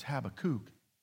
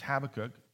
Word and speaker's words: Habakkuk: In fact, some of you are saying, oh, is that how you pronounce it? Habakkuk? I thought it was Habakkuk. Habakkuk. No, it's Habakkuk 2, verse 4.